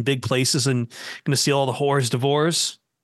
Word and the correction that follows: hors d'oeuvres: Did I even say that right? – No. – Not even. How am I supposed to say that? Come on, tell me. Tell me. – Hors d'oeuvres.